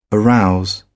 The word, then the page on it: arouse: British English